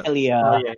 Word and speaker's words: Atelier: We've also got Angels of Death, the Atlar trilogy, which includes Atlar Fearis, Atlar Lydie and Suelle, and Atlar Mysterious, or sorry, Atlar Sophie. If you follow the Atlar games, which I'm sure I'm pronouncing that wrong, it's Atelier or something like that. – Atelier, yeah, I think.